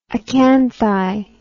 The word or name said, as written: acanthi